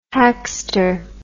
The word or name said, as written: hackster